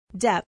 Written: depth

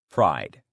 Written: pride

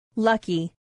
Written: lucky

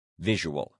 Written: visual

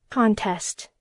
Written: contest

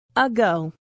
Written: ago

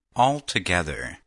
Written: altogether

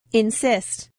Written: insist

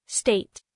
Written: state

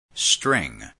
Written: string

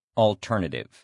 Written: alternative

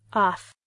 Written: off

